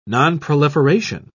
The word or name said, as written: nonproliferation